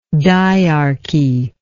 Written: diarchal

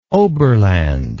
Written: oberland

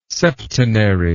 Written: septenary

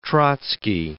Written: Trotsky

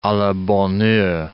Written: a la bonne heure

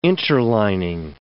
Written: interlining

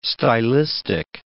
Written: stylistic

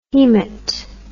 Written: haemat

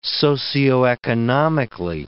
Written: socioeconomically

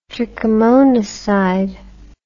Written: trichomonacide

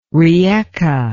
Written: Rijeka

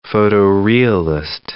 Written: photo-realist

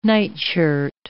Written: nightshirt

